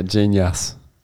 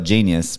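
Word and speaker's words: Jeen-yuhs: Well, at first I thought genius, but then you said yas, and that makes me think of Yas Queen. – Maybe I pronounced it wrong. – Yeah. – But like, yeah, it's the Kanye documentary. – Ah, yeah. – Genius. – Genius. – I think it's Y-U-H-S. J-E-E-N-Y-U-H-S.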